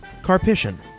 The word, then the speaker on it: Karpyshyn: And we're joined in the cantina this week with Star Wars author Drew Karpyshyn.